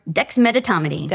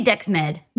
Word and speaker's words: Dexmedetomidine: Dexmedetomidine. – Dexmedetomidine. I didn't say it right still. – We say Dexmed.